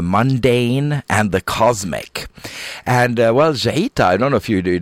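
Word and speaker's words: Jeita: The track was called Jaita, and I'll spell that for you. It's J-E-I-T-A, and it's called Jaita Upper Cave.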